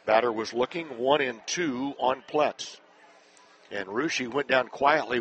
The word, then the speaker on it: Ploetz: Connor Pets, or I should say Pletz, a freshman steps in, spelled P-L-O-E-T-Z. You don't pronounce the O.